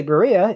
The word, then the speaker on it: Berea: Pronounce Berea or Medina. Medina. Oh, yeah. Berea. Berea? – I just spun that Pokestop last week. – I know you're not from Cleveland. If you don't say Berea, it's so scary.